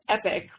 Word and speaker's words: epoch: Well, so my opinion is that if you say epic, which is how I would prefer to pronounce it, it sounds too much like EPIC, which is not necessarily a bad thing, because then you'd be like, epic Rust 2018. But it can be confusing.